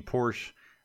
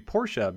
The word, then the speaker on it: Porsche: So if you're wondering, I've been saying Porsche my whole life until recently. And I was told that it's actually Porsche because it is based on the way the family's name is pronounced in German.